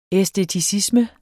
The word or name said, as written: æsteticisme